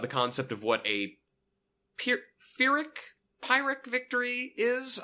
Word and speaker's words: Pyrrhic: I don't quite know how to pronounce it because there's a P and an H and a Y and a couple of R's in a weird place in that. – Tim says later, a Pyrrhic.